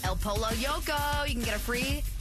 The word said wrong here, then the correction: El Pollo Loco: El Pollo Loco.